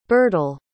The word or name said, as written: birtel